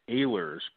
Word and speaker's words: Ehlers: My last name is Ehlers, pronounced with a long A sound, but just call me Ed.